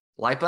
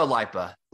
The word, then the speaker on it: LIPA: How do you pronounce that? – Lipa. I go Lipa. – Lipa. Okay. I like that. Yeah, that works for me.